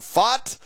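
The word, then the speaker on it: Fought: What's funny is that he's pronounced it was John Fott. – Fott, okay.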